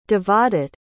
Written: Devadutt

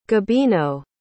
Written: gabino

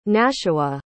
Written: nashua

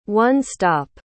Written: one-stop

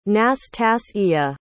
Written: nastasiya

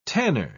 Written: tanner

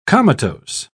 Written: comatose

